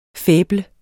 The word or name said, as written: faible